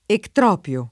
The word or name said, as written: ectropio